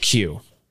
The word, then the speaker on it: queue: In the subject line, you have to spell out the word Q. Yes. How do you spell it? – Q-U-E-U-E.